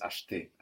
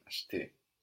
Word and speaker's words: acheter: But to buy is most commonly heard as acheter, acheter.